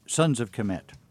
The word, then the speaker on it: Sons of Kemet: Let me ask you about, I hope I pronounced this correctly, Sons of Kemet.